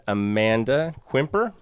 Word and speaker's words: Amanda Quimper: So we've got a guest today, and Amanda Quimper.